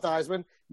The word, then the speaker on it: Theismann: Number one, it's actually pronounced Theismann. – Oh, really? – They started pronouncing it Theismann in college because his college coach was like, Theismann rhymes with Heisman. And if you play by Theismann, you'll win the Heisman.